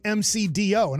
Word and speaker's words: McDo: It is called McDo. In France. It's not called McDonald's. It's okay. – M-C-D-O, pronounced McDo.